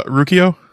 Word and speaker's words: Rakuyo: The Ryoko. – Oh, the Rakuyo. – Rakuyo. There you go. I knew I was saying that wrong.